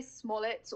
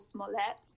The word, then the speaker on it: Smollett: So you got the pronunciation right. We say Smollett or Smollett.